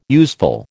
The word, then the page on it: useful: American English